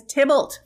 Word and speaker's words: Tybalt: Tybalt. No, no, no, no, no. His name is Tybalt.